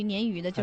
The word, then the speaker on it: 鲶鱼: 它正确读音是鲶。 – 哎，鲶鱼。 – 哦。 – 但是呢，这个当地人呢，就是由于发音的关系啊，经常念成就是鲶鱼。 – 哦。 – 实际上呢，它是想发鲶的那个音。 – 但是它呢了不分。 – 啊，对，它又发不准。